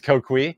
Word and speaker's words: Coqui: It's Coqui.